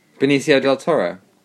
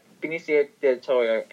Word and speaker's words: Benicio del Toro: Benicio del Toro. – Yeah, Benicio del Toro.